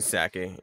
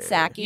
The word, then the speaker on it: Psaki: Is that, am I pronouncing it right? – Psaki. Yeah. – Psaki. – Yeah, it's close enough.